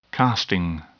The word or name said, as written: casting